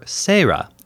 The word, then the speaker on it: Seira: What about Sayla, or Seira, as her name is pronounced in Japanese?